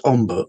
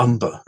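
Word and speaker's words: Ombre: which we call Hombre, or it used to be pronounced Omber or Umber, and is referenced.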